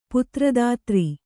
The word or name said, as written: ಪುತ್ರದಾತ್ರಿ